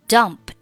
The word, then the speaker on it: dumped: Dump 加上 ed, 它读音是 dumped. – Dumped. – 那在句子当中呢，这个 dumped 末尾的 p 和 t 都可以失去爆破，不用发音。 – She dumped me. She dumped me. – 我们在发到这个爆破音的时候呢，只需要把嘴巴闭起来就可以了。 – She dumped me. She dumped me. – 下面 why? – I mean, why did she part company with you after 7 years together? – 这里 did 末尾。 – Part, 末尾 t, 不用发音。